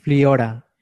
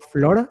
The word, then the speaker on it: Fliora: se llama como Fliora, no Flora, sino Fliora. Igual se más un poco extraño de pronunciar, pero Fliora es un muchachito.